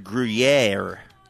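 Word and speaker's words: Gruyère: I believe it's pronounced Gruyere. – Okay. Well. – But that's the problem with the rhyme, is we're here. Gruyere does not rhyme with here.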